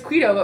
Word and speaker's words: Qdoba: It's because in college, my one roommate in particular, she'd be like, wait, it's Qdoba. I was like, oh, okay, well, you want to go to Qdoba? – It's just Qdoba, isn't it?